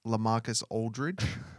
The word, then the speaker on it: LaMarcus Aldridge: So before, in the past, whenever this was, you said LaMarcus Aldridge.